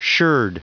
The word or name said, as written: sherd